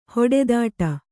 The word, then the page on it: ಹೊಡೆದಾಡು: hoḍdāḍu; kannada